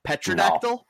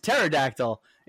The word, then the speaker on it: pterodactyl: and he kept pronouncing it petrodactyl. – No. – Yeah. And I thought he was just doing a bit, so no. I was like, oh, that's not a petrodactyl, that's a pterodactyl.